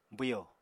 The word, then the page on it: bwyo: apatani